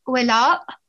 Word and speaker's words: Voila: It's Voila, and it ends with a glottal sound.